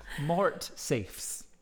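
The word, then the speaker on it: mortsafes: Safes. The E isn't pronounced as a single syllable. Mort safes.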